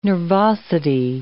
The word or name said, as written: nervosity